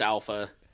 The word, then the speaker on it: ALPHV: it's pronounced Alpha. I think it just stylizes it as ALPHV.